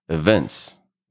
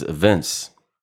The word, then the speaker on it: evince: The emphasis is on the second syllable, okay? Evince. – Event. – Kind of like event, but is Evince. You may spell it for you? It's E-V-I-N-C-E.